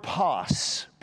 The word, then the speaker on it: pas: All four of those words, whosoever and all, are the Greek word pas. P-A-S would be the phonetic pronunciation of that, pas.